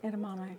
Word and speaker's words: edamame: Chiba Green Organic Edamame. – Mm. – Am I saying that right? – Edamame.